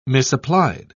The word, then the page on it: misapplied: British English